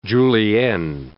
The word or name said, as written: julienne